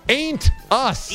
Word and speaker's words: ain't: Anus. – Ain't. – Yeah, that's what I'm saying. – Ain't. You got to pronounce the T there. – Anus. – No! Ain't us.